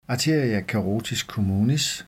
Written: a. carotis communis

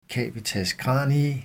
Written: cavitas cranii

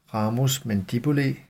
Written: ramus mandibulae

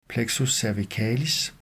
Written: plexus cervicalis

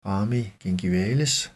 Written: rami gingivales

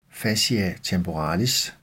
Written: fascia temporalis

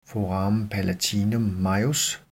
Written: foramen palatinum majus

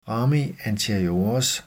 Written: rami anteriores, rygmarvsnerver

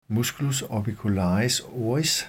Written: m. orbicularis oris

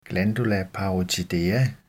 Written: gl. parotidea

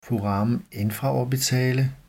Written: foramen infraorbitale

